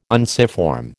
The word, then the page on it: unciform: American English